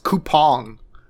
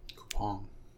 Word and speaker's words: Coupang: It's spelled Coupang, but I learned it's pronounced Coupang. – Coupang.